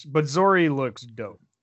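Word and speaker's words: Zorii: In the character lineup, her name's actually listed, and it's Z-O-R-I-I. I imagine that's Zori. I don't know how you guys pronounced it. – Yeah, that's Zori. Yeah. – All right, Zori.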